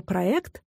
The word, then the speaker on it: проект: И заметим, что слово «проект» пока все еще держится старых правил. Его мы произносим через «э».